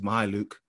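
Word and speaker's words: Mykhailiuk: And actually with Zvi Mikhailiuk, I checked how you say it, is Mikhailiuk.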